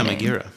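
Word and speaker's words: Megaera: And of course, there's a whole Medusa character, which is actually not Medusa, it's Megara, I think is how they pronounce it. – Yeah, Megara.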